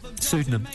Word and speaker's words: pseudonym: I do go under another synonym. Is that the word? Pseudonym. – You're telling the story. – Pseudonym. I just mispronounced that.